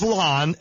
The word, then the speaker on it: Lon: My name is Lon, L-O-N. I've got to spell it because everybody calls me Ron.